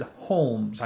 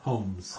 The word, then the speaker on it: Holmes: Although I pronounced it Holmes. I put a little L in there. He's telling me not to put an L. – Holmes. – Holmes. Sherlock Holmes.